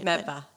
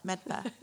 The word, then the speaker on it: Medford: Medford. From, from Medford. I can't even say it right. – Medfa. – Medfa. Medford to be exact.